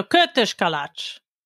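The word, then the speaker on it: kürtőskalács: So kurtos kalács, that's how you say it, right? – Kurtos kalács, yes.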